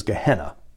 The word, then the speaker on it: Gehenna: Hades sounds like the original word, Hades, and in the original language, the word for hell is Gehenna, which people usually just pronounce as Gehenna.